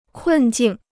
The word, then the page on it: 困境: kùn jìng